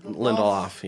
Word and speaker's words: Lindelof: Lindelof, yep.